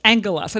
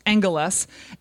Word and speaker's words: angelos: It's pronounced angelos. I know it doesn't look like that, but it's pronounced angelos.